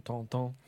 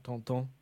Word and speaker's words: Tintin: I mean, I was just taking a look at the Tintin's, or the French word, French pronunciation, Tonton, for people who know it. And it's French version, Tonton.